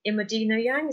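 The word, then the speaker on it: Immordino-Yang: In a previous podcast you made with Mary Helen Imodino Yang, is that how you say her name? – Yeah.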